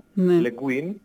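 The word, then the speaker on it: Le Guin: Παρ' όλα αυτά οι Αμερικανοί τα προφέρουν διαφορετικά, Λε Γκουίν. Βέβαια στα ελληνικά έχει επικρατήσει το Λε Γκέν. – Η γαλλική προφορά.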